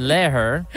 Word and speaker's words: Lehrer: Say his name again. – Lehrer, L-E-H-R-E-R. How would you pronounce that? – Lehrer.